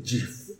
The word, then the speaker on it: GIF: No, se escribe GIF, pero se dice GIF.